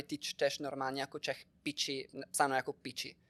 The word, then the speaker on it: picci: P-I-C-C-I. – P-I-C-C-I. – Pici. Jako napsáno pici, ale vyslovené pokud... piči. Mm-hmm. Protože dvojité C, italsky to tvrdý Č.